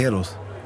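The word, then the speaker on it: Héroes del Silencio: Eros del Silencio. – Nee, Eros. – Ja, das H spricht man nicht.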